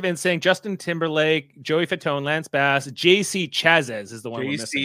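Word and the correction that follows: Chasez: JC Chazay.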